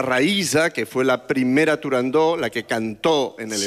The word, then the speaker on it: Turandot: Turandot. – Turandó. – Turandot. – No. – Turandó. – No. No se dice, no se debe pronunciar Turandot. – Ah, no, Turandó. – La ópera se titula Turandó.